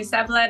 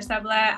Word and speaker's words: Seble: Yes, call me Seble, Dr. Seble. I'm just so happy that you pronounced my name so perfectly and beautifully, and yeah.